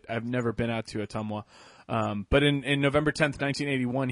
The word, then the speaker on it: Ottumwa: Yeah, Ottumwa, sorry. It was the pronunciation I was wondering.